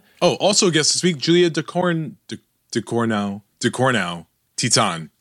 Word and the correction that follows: Julia Ducournau: After the break, Titan director Julia Ducournau.